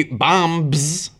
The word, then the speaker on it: bombs: It could be bombs, but the B is silent.